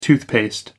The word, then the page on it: toothpaste: American English